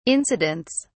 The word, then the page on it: incidents: British English